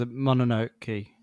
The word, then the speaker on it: Mononoke: I'll have to seek that one out, because Mononoke, is that how you pronounce it? I don't know. – Yes.